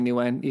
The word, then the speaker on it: Nguyen: Is that how you say it? When? I think that's how they say it. – Oh, is it? – It depends on the person. One of my best buddies, Turner, he says he always goes by Nguyen, even though he said that sometimes back home they say Nguyen.